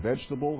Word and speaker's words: vegetable: You look at that word vegetable that has an E after the G before the T. Some people say it like this, vegetable. I kind of skip that. I don't know if you say it that way or not, but you can say it all kinds of ways.